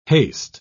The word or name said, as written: haste